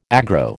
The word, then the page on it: aggro: American English